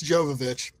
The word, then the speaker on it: Jovovich: It's Jovovich.